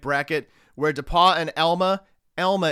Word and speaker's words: Elma: I'm not going to do a whole P101, but Elma is how that's pronounced. They are very, very insistent that you pronounce it Elma. Just like the folks in Western Massachusetts don't want to hear any H when you talk about Amherst. This is how you pronounce Elma.